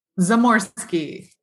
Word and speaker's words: Zamorski: Zamorski.